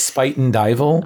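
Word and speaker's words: Spuyten Duyvil: Yeah, because originally it's Spite and Dival as the conductors call it.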